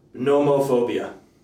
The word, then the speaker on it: nomophobia: Nomophobia. – Fear of, having, what, say it, can you tell? Nomophobia. N-O-M-O, kind of like homo, like you, homosexual. – Okay. – But nomo, N-O-M-O, phobia.